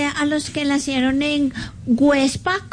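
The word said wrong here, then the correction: Güepsa: Perdón, Huepsa. Websa. ¿Ah? Con diéresis. – Websa.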